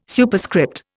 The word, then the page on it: Superscript: Indian